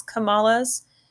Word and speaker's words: Kamala: it's pronounced Kamala. So the accent is put on the first syllable, Kamala. Okay. Or if in her own biography, she says it's comma, like C-O-M-M-A, law, like the punctuation mark, comma, law.